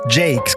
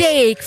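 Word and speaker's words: JVKE: ut Golden Hour med, vi snackar om hur det uttalas, Jake ska det tydligen uttalas. – Jake, förstås, för det är ju ett A upp och ner.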